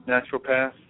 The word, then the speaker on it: naturopath: See if I say it this time right. Naturopath. Did I say it right? – Naturopath, yeah.